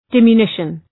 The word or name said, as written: diminution